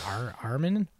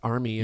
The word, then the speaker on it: Armie: I'm like, Armin? And I think you corrected me. You're like, it's Armie. And I'm like, it's Armie, yeah.